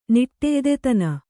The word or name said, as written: ನಿಟ್ಟೆಯ್ದೆತನ